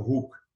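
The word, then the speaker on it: Hoek: But the best pronunciation is like Dr. Hook, so Hook, H-O-O-K, that's how you pronounce it.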